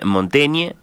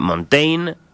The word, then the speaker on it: Montaigne: En Francia lo escuchábamos a Piccoli, dicen Montaigne. Nosotros estamos más acostumbrados a Montaigne, castellanizando. Le podemos decir Michel o Miguel, ya lo vamos a ir decidiendo.